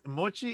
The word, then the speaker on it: mochi: He is going to be making moichi, Japanese ice cream. – Okay, so am I saying it right? – Mochi is the Japanese rice cake,